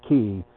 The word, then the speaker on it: quay: I'm not going to say that, you know, Q-U-A-Y, pronounced key,